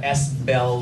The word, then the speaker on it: S Bell: I have people who are like, "Why are you Spell?" And I'm like, "It's Southern Bell. It's not Spell, but okay."